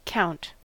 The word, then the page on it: count: American English